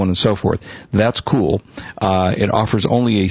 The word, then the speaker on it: Cuil: Again, s- pronounced Cool, spelled C-U-I-L com, That's one of the many search engines around.